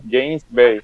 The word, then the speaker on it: James Bay: O es, así es, se pronuncia, se escribe James, pero se pronuncia James, obviamente, ¿verdad? James Bay.